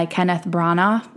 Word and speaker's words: Kenneth Branagh: And it is the third of the series that have been produced and directed by Kenneth Branagh.